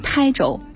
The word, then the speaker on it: 台州: 嗯，就比如说地名吧，像浙江台州，它就是电视台的台，但是它约定俗成就要念成台州。